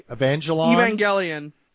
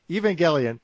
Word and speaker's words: Evangelion: Maybe my favorite track on the album is, I think I'm pronouncing this correctly, Evangelion. – No, I'm not pronouncing it correctly. Evangelion.